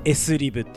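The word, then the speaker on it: SLIB: で 1 個のさ頭の S だけは S って読んで、あとはリブって読みますが S リブって言います、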